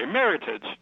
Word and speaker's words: Meritage: You say it the American way, Meritage.